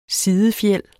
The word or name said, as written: sidefjæl